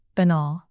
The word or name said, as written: banal